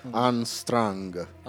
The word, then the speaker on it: Unstrung: Si legge Untag. – Unstrung. Pinocchio Unstrung.